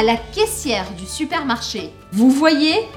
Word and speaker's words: voyez: O Y em francês ele tem um som que equivale a dois Is. Então eu falo vous voyez. Por quê? Porque o primeiro I faria a combinação com a letra O e eu falaria wa, wa. E o segundo I, hipotético, eu pronuncio. Então fica vous voyez.